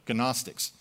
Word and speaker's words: Gnostics: On the other hand, John was addressing a different cult in a lot of his writings, a group called the Gnostics, that's with a silent G, Gnostics, who claimed that Jesus was in fact not the divine creator at the beginning of the book of Genesis.